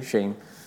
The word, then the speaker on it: Shein: So Shein, I never know how to say it. – Shein.